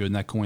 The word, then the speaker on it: Nacon: Euh, on, va, on va les appeler Nacon, hein, on va prononcer ça Nacon puisque c'est un éditeur français.